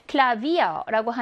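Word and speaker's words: Klavier: Klavier라고 하는 저 단어는 V가 있어요. 하지만 V가 모와 모 사이에 위치하나요? 모음과 모음 사이에 위치해요. 아 하고 복모음 이의 사이에 위치하거든요.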